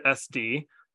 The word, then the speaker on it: SD: An SD stands for discriminative stimulus, and when it's written, it is a capital S with a superscript capital D. So if you're ever writing it or if you're reading it in literature, that's how you will see it. But generally, you pronounce it SD.